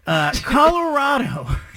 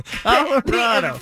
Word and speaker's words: Colorado: Colorado. Colorado. – The emphasis was wrong, the wrong syllable.